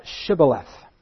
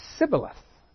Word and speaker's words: Shibboleth: If he said, "Nay, I'm not an Ephraimite, I'm just crossing the Jordan to do my business," then said they unto him, "Say now Shibboleth." And he said, "Sibboleth," for he could not frame to pronounce it right.